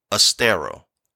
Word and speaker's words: Ostara: And when I say Oster, it's spelled E-O-S-T-R-E or Ostara, O-S-T-A-R-A, which this goddess is the same goddess that we've been talking about along with the other names.